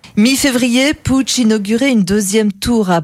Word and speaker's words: Puig: Ça s'écrit P-U-I-G, mais ça se prononce Puig.